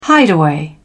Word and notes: hideaway: British English

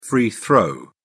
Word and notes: free throw: British English

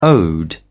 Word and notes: ode: British English

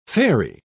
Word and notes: faerie: British English

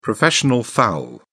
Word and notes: professional foul: British English

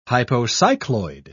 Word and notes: hypocycloid: British English

